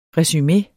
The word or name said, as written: resumeet